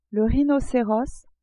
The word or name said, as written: rhinoceros